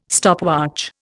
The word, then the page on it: stopwatch: Indian